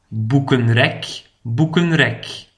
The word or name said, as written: het boekenrek